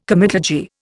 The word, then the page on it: comitadji: Indian